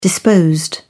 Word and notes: disposed: British English

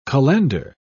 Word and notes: cullender: British English

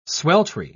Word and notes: sweltry: British English

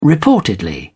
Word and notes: reportedly: British English

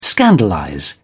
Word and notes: scandalize: British English

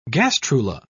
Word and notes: gastrula: British English

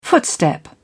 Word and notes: footstep: British English

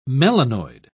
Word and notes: melanoid: British English

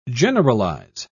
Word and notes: generalise: British English

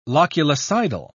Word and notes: loculicidal: British English

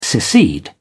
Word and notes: secede: British English

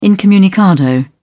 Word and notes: incommunicado: British English

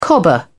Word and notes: cobber: British English

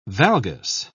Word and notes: valgus: British English

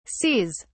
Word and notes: seas: British English

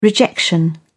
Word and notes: rejection: British English

